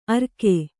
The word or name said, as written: ಅರ್ಕೆ